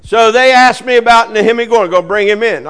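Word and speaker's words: Nehemia Gordon: And one of the best ways, I was with a guy named Nehemi Gordon. That's the way you say his name. Nehemi Gordon.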